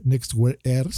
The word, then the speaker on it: NXTWEAR Air: Pues bueno, una compañía que yo no me la esperaba, que se llama TCL, TCL, lanza estos nuevos lentes que se llama Nextwear Air. Se escribe NXTWear Air, ¿no?